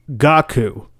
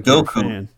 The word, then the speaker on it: Goku: Dude, I heard you pronounce it Gaku one time. – Gaku? Like Goku. – Yeah, I know you're trying. What? You're trying. He mispronounced Goku's name.